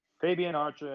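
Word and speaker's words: Fabian Aichner: Fabian Archer and Marcel Bartel, if I said it right.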